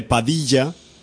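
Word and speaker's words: Padilha: Es un señor que se llama José Padilla, pero hay que pronunciarlo con acento brasilero.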